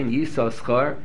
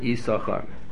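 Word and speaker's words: Yissachar: So many have such a practice that they lay Yisoschar until, or they lay twice. Yisachar, Yisoschar, they say twice, up until Parshas Pinchas. Parshas Pinchas on, the only name, they only pronounce it Yisachar.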